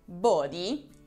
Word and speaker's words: body: Invece la parola body, che sì, pronunciamo proprio così, body, è qualcosa di molto diverso.